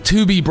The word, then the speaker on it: Tubi: Playing the role of Tubi Bryant is Fat Boy. Pronounced like the network.